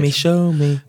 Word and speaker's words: Xiaomi: Is it Xiaomi? – Xiaomi. I thought it was Xiaomi. – It may be. Forgive my pronunciation. – No. I've been meaning to get it right all season. – Xiaomi.